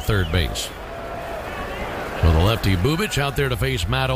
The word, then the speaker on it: Bubic: Spelled B-U-B-I-C, pronounced Chris Bubich.